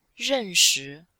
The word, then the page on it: ren4shi5: female